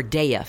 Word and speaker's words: Daya: Now, is it the D-A-Y-A, Daya or Daya Foundation? – Daya. You say Daya. It's a Sanskrit word, so it's pronounced Daya.